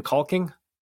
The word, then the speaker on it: caulking: I was just really hoping you would pronounce the L in caulking. That'd be awesome. But hey, it's your show. – I mean, the L is silent. The L is basically silent. So it's your caulking.